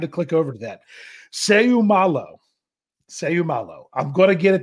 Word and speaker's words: Seumalo: But Isaac Seumalo is the way I'm going to say it. Seumalo.